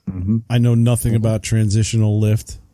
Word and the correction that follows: translational lift: or even how to pronounce it. – Yeah, me either, apparently. – Apparently. – Translational lift? – Translational lift, yes.